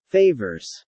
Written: favors

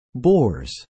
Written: bores

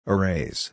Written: arrays

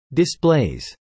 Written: displays

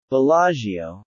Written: Bellagio

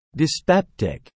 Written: dyspeptic

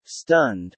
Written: stunned

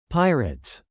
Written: pirates

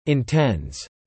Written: intends

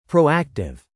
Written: proactive

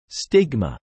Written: stigma

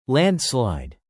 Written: landslide

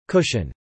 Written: cushion

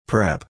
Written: prep

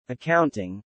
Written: accounting

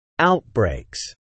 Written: outbreaks

